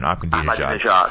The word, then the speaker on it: Ahmadinejad: And can Ahmed, I pronounce his name wrong all the time, Ahmadinejad.